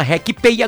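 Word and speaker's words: RekPay: Com a RecPay agora você pode parcelar seu IPVA em até 12 vezes. Baixe o aplicativo da RecPay, se escreve R-E-K e o Pay de inglês, P-A-Y.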